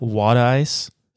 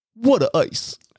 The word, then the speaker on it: water ice: But one of the things outside of cheesesteaks that Philadelphia is known for is water ice, or how they pronounce it, water ice. – Let me try. What a ice. Hey, what ice. – Perfect.